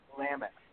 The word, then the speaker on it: Willamette: And then once the word got out, Kristen from, how do you say this, Willamette? – Willamette. – I've been wrong for a long time.